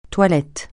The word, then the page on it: toilette: francais